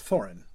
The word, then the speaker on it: Thorin: I mean, the accent is still on the first syllable, but it's not, you don't hold out that vowel really long. It's Thorin. It's not Thorin or something like that. So it's the vowel is a little bit shorter.